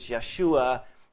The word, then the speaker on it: Yeshua: Remember, Joshua, translated into Greek, which is where the New Testament is written in, is Jesus. So, you know, that's the Greek translation, and that's what we use to say his name. But really, it's the same name as Joshua. So again, remember that that's what they would, they, if you say it in Hebrew is Yeshua, they would say the same thing with Jesus.